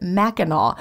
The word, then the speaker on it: Mackinac: The Mackinac Bridge sounded like a hick from Georgia. But she told me to pronounce it Mackinac.